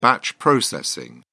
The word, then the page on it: batch processing: British English